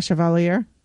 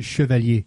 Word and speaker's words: Chevalier: It's not Chevalier. How is it? You say it. – Chevalier. – There you go.